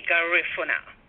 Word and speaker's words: Garifuna: And for people who may not be familiar with the location of where, am I pronouncing it? Is it Garifuna people? That's the right pronunciation? – You can say Garifuna, and I say Garifuna.